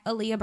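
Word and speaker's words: Ahlya: So A-H-L-Y-A. I know you can spell Aaliyah 800 different ways, but that's how you spell it my way.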